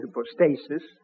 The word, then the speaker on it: hypostasis: Now, when the Greeks used, I'll give the general English pronunciation, hypostasis, it means simply a mode of being, a way of being.